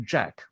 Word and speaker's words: JAAQ: It's called, I suppose it's pronounced Jack, although it's spelled J-A-A-Q. – Mm. – But it's J-A-A-Q stands for Just Ask a Question.